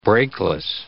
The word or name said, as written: brakeless